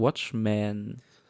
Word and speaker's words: Watchmen: Denn wir haben zusammen, boah, ich muss gerade aufpassen wegen der Aussprache, Watchmen.